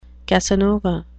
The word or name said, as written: casanova